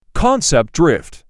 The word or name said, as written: concept drift